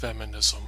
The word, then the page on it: feminism: American English